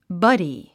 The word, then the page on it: buddy: bʌ́di; バディ